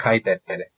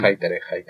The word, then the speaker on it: Hayter: Estábamos discutiendo esto ayer de cómo lo pronunciamos. Podemos dejarlo en Hayter. – Sí, se ve que es Hayter. Es Hayter.